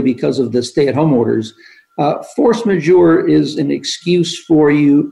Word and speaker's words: force majeure: It's force majeure provisions. Did I say that right? – You did, right.